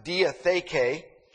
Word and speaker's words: diatheke: The Greek counterpart to this word is pronounced diatheke.